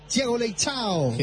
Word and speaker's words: Thiago Leitão: Thiago Leitão.